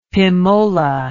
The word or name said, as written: pimola